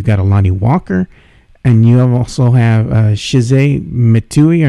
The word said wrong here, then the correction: Chimezie Metu: or Metu. Mm-hmm. I don't know how to say his name. – Yeah, Chimezie Metu.